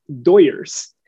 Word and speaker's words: Dodgers: So it's in order to pronounce Dodgers, a majority like Spanish speaking person is going to say, Dodgers. And so it slowly evolved into this Doyers.